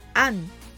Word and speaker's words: and: and reaching for the sun の and は and ではなくアンと発音し